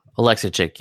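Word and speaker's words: Oleksiejczuk: So how do you pronounce it? Is it Oleksichuk? – Oleksichuk, yeah.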